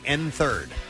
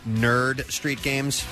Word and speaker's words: Nerd Street Games: I've been saying N3rd. So it's N3RD Street Games. Apparently, that's Nerd Street Games.